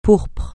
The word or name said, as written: pourpre